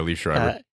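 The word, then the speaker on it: Liev Schreiber: Yeah, Liv Schreiber.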